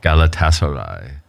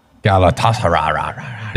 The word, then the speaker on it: Galatasaray: Galatasaray. – Galatasaray.